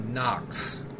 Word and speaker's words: Nox: Nox.